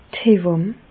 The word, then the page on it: d' Hewàmm: Bas Rhin; Reichshoffen